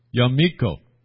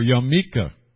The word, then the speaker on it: yarmulke: Most people refer to it as a yarmulke, or sometimes pronounced yamiko, or yamika, or yamiki.